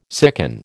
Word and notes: sicken: American English

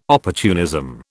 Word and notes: opportunism: British English

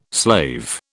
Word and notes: slave: British English

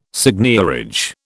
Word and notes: seigniorage: British English

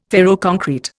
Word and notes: ferroconcrete: Indian

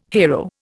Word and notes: hero: Indian

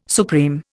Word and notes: supreme: Indian

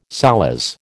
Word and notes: solace: American English

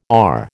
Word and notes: our: American English

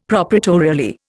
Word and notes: proprietorially: Indian

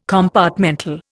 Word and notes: compartmental: Indian

